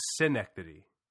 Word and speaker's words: Schenectady: It's now the town is Schenectady. Is that yes. – how it's pronounced? The film is Schenectady.